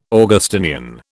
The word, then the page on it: Augustinian: British English